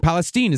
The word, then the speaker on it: Palestine: Palestine, is that how they say it? – That's how they say it.